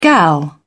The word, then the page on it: gal: British English